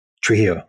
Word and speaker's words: Trujillo: Trujillo, yes.